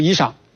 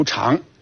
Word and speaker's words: 裳: 然后我们再来看这个裳，这个字呢有两个读音，我们日常生活中呢读衣裳，但是在古文中呢它在指下衣的时候读裳。这个字在篆文中上面呢是上，表示高级正式的意思，下面呢加了个衣，合在一起呢就是表示遮蔽下体，乃服衣裳，就才开始穿衣裳。